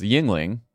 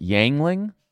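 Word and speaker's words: Yuengling: But I did notice that he was on a podcast right after he started running where he talked about one of the most popular beers in Pennsylvania is, of course, is Yingling. And he called it Yangling. – I mean, that, you'd have to leave the state. It would be like mispronouncing. – Or it was one of the syllables was very badly pronounced, which is crazy to me because like, what are you doing, man?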